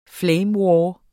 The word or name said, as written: flame